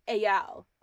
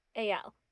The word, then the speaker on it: Ayal: He said it's Ayal. – Ayal. – But like emphasis on the second syllable, mm-hmm. and instead of like an E, it's more like an AY sound. – Ayal.